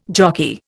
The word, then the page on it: jockey: Indian